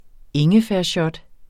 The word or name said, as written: shot